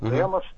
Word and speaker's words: демос: Вот как раз речь шла о слове «демос». Именно так оно произносится, вот «демос».